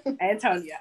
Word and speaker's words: AnTonia: Antonia.